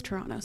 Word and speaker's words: Toronto: Pronounced it properly. You dropped the second T. It's Toronto, so you're Toronto.